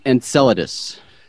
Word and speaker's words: Enceladus: So don't feel bad, but it's pronounced Enceladus.